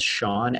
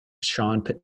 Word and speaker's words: Shaan: I'm on Instagram at, my first name is spelled Sean, S-H-A-A-N, last name Patel, Sean, at Sean Patel.